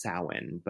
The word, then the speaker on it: Samhain: The letters M and H next to each other, I believe in Welsh or Celtic pronunciation, creates the W sound. So that's how it's pronounced, Samhain, but it's spelled Samhain.